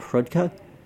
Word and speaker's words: hrudka: And they would make all of the Easter breads, the nut rolls, the pascas, and we'd make an egg cheese called hrudka. – Hrudka? – Yeah. – Tag hrudka. That one. It's spelled like it sounds.